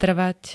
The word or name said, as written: trvať